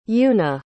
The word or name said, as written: yuuna